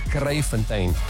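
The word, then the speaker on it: Kraaifontein: That's where he's always at, Krayfontein. That's the right way to say it.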